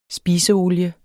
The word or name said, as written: SPISEOLIE